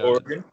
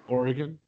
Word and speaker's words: Oregon: Oregon. – Oregon.